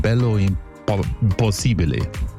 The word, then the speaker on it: Bello Impossibile: Gianna Nannini mit Bello Impossibile. Habe ich falsch ausgesprochen? – Nein.